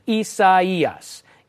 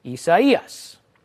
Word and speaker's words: Isaias: Isaias. Isaias. I'm pretty proud of my Spanish pronunciation there.